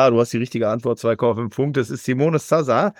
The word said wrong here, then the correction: Zaza: Muss man sagen, also mit Z geschrieben, vielleicht wieder Zaza ausgesprochen oder so immer.